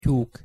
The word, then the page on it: tyuk: Hungarian